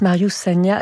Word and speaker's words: Marius Sagna: Il s'appelle juste Marius Sagna. Alors, j'espère que je prononce bien son nom. Oui, c'est ça.